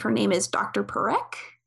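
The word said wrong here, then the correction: Parcak: Oh, Sarah Parkak. – Parkak, that's how you say it.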